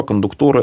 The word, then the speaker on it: кондукторы: Кондукторы – это аналог, именно с таким ударением,